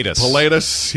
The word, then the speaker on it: Pilatus: Pilatus.